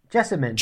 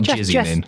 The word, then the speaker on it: Jessamyn: It's spelled J-E-S-S-A-M-Y-N, Duke. – Jessamine. – Jasmine. – Yeah, not Jasmine.